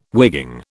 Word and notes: wigging: British English

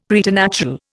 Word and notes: preternatural: Indian

